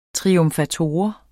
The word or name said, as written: triumfator